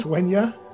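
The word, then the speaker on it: Uaine: Yeah, when you originally created this, I have here the inhabitants here worship tree gods called Wenya, which is spelled U-A-I-N-E. I'm sure with some kind of accent that I couldn't put here. And yeah. Pronounce Wenya. – Wenya, yep.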